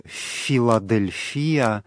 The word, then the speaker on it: Филадельфия: Само это слово «Филадельфия», греческое ударение в нем будет «Филадельфия», оно значит «братолюбие».